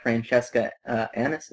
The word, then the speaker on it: Francesca Annis: Well, here's the thing about this movie, and he's part of it, and so is the Widow of the Web, Francesca Annis.